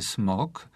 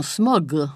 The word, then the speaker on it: smog: Ja teraz mówię tak swobodnie i mówię smog, wymawiając na końcu tego rzeczownika głoskę bezdźwięczną k, smog. – Ale niektórzy mówią smog.